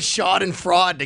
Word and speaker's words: Schadenfreude: I heard Jeremy say "shod and fraud." – Is that how you say it? Schadenfreude? – So here, Schadenfreude. – Here we go. – Schadenfreude.